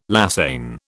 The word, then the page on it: lasagne: British English